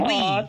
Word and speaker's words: oui: And we. – And it's spelled O-U-I, but it's pronounced we.